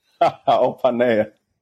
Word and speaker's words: Opaneye: Have I pronounced your surname correctly this time? – Opanaya. – Opanaya. – It's okay. – I'm going to get it right. Femi Opanaya.